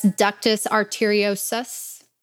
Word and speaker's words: ductus arteriosus: During fetal development, multiple shunts help direct blood flow to vital organs such as ductus arteriosus.